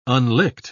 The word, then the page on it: unlicked: British English